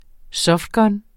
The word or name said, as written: softgun